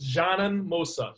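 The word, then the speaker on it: Džanan Musa: It's pronounced Janan Musa. Janan, like John.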